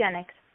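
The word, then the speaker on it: Isagenix: And on your website, you also offer the, how do you pronounce that, Isagenics line of products? – Isagenics.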